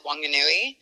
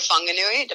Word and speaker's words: Whanganui: Yeah, I met him in a small town in the North Island called Whanganui, or Whanganui, depends on how you want to pronounce it.